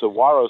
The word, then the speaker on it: Saguaro: Saguaro Sisters. It's spelled with a G, but pronounced with a W.